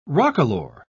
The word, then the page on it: roquelaure: British English